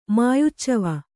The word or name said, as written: ಮಾಯುಚ್ಚವ